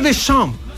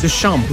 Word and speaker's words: Deschamps: Didier Deschamps. – Ah. – Lo pronuncié bien, ¿o no? – 10 de 10. – Deschamps. – Deschamps.